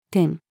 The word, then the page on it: 点: female